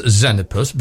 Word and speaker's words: Xenopus: It's pronounced Xenopus, but it's spelt X-E-N.